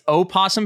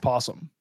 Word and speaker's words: opossum: I'm pretty sure that opossum, the way you're pronouncing it, is just the way that you spell possum. And but then it's just pronounced possum. – So it's O-P-O-S-S-U-M papers. – Yes. – Okay. – Correct. Yeah. The O is just silent.